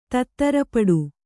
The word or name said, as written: ತತ್ತರಪಡು